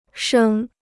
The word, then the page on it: 升: shēng